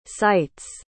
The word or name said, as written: cites